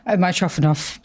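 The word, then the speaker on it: Mitrofanoff: I'm going to read it now and butcher the pronunciation, but Mitrofanov? – Mitrofanov.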